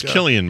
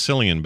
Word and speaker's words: Cillian: Like, so it's Killian Murphy, right? – Killian Murphy. – Exactly. Yeah, I've always said Killian, and everyone always tells me it's Cillian, but no, I think it's Killian.